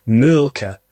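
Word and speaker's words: Mölkky: Am I pronouncing it right? – We use Molky. Most of the people we play against use Molky, but the Finns have a very unusual pronunciation, which, please forgive me, I'll try it. It's Mulka. So I think that's, something a bit more like that.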